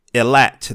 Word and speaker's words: Eilat: Allen, what I find is the Ancient Ones, Prophecy of Eilat. Did I pronounce that right? – You pronounce it correct, yes.